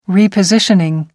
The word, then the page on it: repositioning: American English